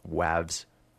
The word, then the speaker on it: Wavs: If you like the other act right now called Waves, except spelt with a double V, so that's like Wavs. Wavs, yeah. It sounds like that.